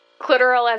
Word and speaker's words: clitoral: Like, clitoral as in rhyming with literal.